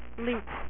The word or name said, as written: Leech